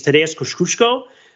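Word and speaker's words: Tadeusz Kościuszko: Uh, I've been told that the way to pronounce his name is Tadeusz Kosciuszko, but uh, I would call him Kosciuszko, right? If I were just to try to read it English phonetically.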